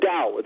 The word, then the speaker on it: Tao: I see a title here, the the Tao of Bill Bill Murray. Am I saying that correctly? That looks interesting too. – Uh, the Dow, the Dow of Bill Murray, ah.